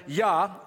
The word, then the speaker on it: Jah: And then Yah, J-A-H, the J is pronounced with a Y sound.